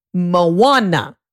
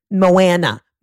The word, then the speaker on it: Moana: Moana. That's how you pronounce it. You don't say Moana.